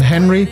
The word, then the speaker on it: HNRY: And Henry, it's spelled H-N-R-Y, Henry,